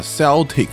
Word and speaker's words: Celtic: 也有人念成叫 Celtic style。